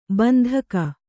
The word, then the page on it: ಬಂದೂಕು: bandūku